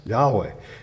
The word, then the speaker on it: Yahweh: That's how that's pronounced, Yahweh.